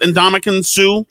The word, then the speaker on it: Ndamukong Suh: How do you say it? Ndamukong Suh. – Correct. – Nailed it.